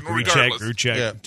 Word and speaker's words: Grichuk: Did I screw up the pronunciation? – Grichuk.